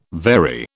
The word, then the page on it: very: British English